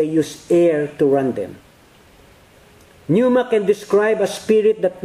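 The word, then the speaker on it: pneuma (πνεῦμα): It's spelled P-N-E-U-M-A, pneuma.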